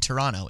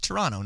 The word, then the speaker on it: Toronto: Everyone knows, well, maybe not everyone knows, but if you know, you know that you don't pronounce the second T in Toronto. It's Toronto, not Toronto.